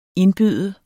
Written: indbyde

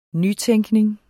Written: nytænkning

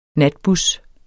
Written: natbus

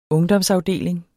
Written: ungdomsafdeling

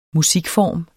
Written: musikform

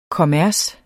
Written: kommers

